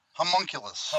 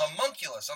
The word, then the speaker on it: Homunculus: I was telling earlier, and I came across some crazy stuff on Harmonicus. If I'm pronouncing that right again? – Homunculus. – Homunculus. I'm sorry.